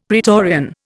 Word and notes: praetorian: Indian

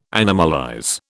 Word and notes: animalize: British English

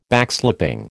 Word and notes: Backslapping: American English

